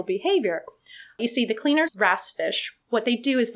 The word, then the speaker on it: wrasse: These are called cleaner wrasse. It's spelled WRASSE, but pronounced wrasse.